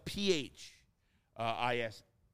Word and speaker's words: phishing: Phishing is the word is spelled P-H, I-S-H-I-N-G. And it's pronounced phishing.